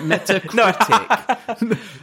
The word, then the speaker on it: Metacritic: It's actually Metacricket. No, Metacritic. Metacritic. But I always say it wrong, so it's just now Metal Crickets.